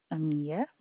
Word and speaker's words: Amia: Amia. – Amia? Could you spell it? – A-M-I-A. – Okay. A-M-I-A.